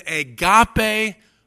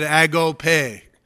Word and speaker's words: agape: Jesus is telling us to have an agape love for our enemies. A-G-A-P-E. Agape, or some pronounce it agope.